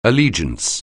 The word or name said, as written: allegiance